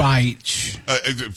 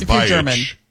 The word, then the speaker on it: Beich: I was trying to pronounce a reporter's name, and it's spelled like bitch, something like it's like that. – But it's pronounced exactly that. – Beich. – From what we understand, right, it's pronounced Beich.